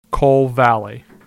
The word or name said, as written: Coal Valley